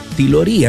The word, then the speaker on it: Tilorí: Tilorí, así se llama, acento en la I.